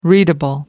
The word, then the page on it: readable: American English